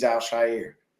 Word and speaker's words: Al-Shaair: And his name is Aziz Al-Shaair. Okay? Mm. And people, that is his name. Al-Shaair, not Al-Shaair, okay? It's not S-H-I-R-E.